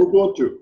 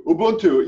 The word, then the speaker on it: Ubuntu: Ubuntu. Ubuntu.